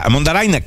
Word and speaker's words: Mondarain: Poi sono curioso di vedere Mondarain, non lo conosco come giocatore. Mondarain, che è la pronuncia che usano tutti i cronisti argentini, quindi la diamo per buona,